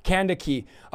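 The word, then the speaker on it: Kandake: He's a treasurer and a personal attendant to the Queen Candace, which is Candica, is actually how you say her name, but let's just say Candace for the sake of pronunciation. And it's spelled Candace, but it's really Candaki.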